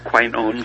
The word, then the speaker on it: quinones: The quinones.